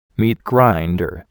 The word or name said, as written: meat grinder